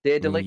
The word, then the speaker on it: Daedalic: Daedalic.